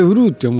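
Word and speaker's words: 閏: そうそう、うんうん、でこのうるう年って、うんうん、門構えに王様の王って書くんですけれども、うん、すごい字だね。それを、ね、それうるうって読むんだ。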